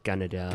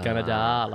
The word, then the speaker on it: Gunnedah: But you've told me Gunnedah. Did I say that right? – Close. It's more with an R, like Gunnedah. – Gunnedah, like Canada.